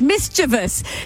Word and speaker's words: mischievous: It isn't mischievous. It rhymes with grievous, mischievous. Susie said so many people say it incorrectly, so that's fine, but is it?